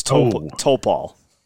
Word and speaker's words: Topal: Close. It's Topal. – Topal. Oh, I had the wrong emphasis on the wrong syllable.